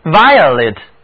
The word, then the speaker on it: violet: violet 其中的字母组合 io 读音是 ia，而字母 e 读音是短元音 e。紫罗兰或者表示紫罗兰颜色的就是这个词，跟我读 violet。